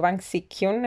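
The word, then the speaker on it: Bang Si-hyuk: Y Bang Ching hyuk o Bang Sik-hyun en verdad, como quieran pronunciarlo, eh, fue un hombre con mucha suerte.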